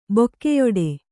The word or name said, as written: ಬಗೆಯಿಡು